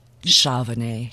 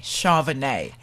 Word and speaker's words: Shuveny: The spelling is S-H-U-V-E-N-Y. – That's correct. – But the pronunciation is... – Charvenay. – Charvenay.